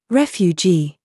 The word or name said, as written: JUDGE